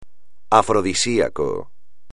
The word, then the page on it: afrodisíaco: espagnol